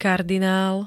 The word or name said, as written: kardinál